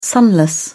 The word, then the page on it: sunless: British English